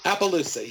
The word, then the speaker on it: Appaloosa: is the Appaloosa, I think I said that correctly, the Appaloosa horse. – Appaloosa, yeah.